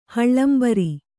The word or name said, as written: ಹಳ್ಳಂಬರಿ